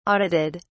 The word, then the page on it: audited: British English